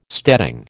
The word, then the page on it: steading: American English